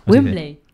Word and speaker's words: Wembley: Wembley. – Wembley, pues lo he dicho igual, yo no, soy como el Chavo del 8, ¿y cómo dije? – ¿Dónde lo viste?